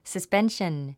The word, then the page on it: suspension: səspénʃən; サスペンション